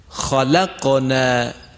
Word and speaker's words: خلقنا: خلقنا خلقنا، در این کلمه خا توجه دارید که درشت تلفظ شد، ما نگفتیم خلقنا خلقنا.